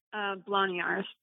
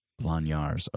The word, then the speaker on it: Bloniarz: How do you say Chris's last name in the band? – Blanyars. – Blanyars. Okay.